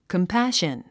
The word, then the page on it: compassion: kəmpǽʃən; コンパション